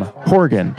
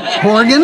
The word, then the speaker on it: Horgan: Horgan? – Horgan, yes. Like Morgan with an H.